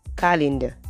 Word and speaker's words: calendar: There are no two E's, so meaning this word ends with AR, it doesn't end with ER, all right? And the pronunciation is calendar, not not calendar. The sound is not so you want to say calendar. Calendar, not calendar. Calendar, that's the right pronunciation.